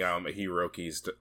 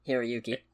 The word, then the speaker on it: Hiroyuki: Yeah, was playing Hiroki's daughter. – Hiroyuki.